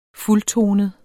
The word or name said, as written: fuldtonet